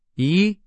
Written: 移

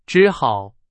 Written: 只好

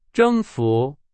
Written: 征服

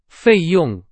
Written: 费用

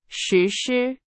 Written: 实施

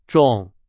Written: 种

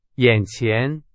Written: 眼前

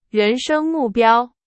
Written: 人生

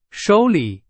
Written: 手里

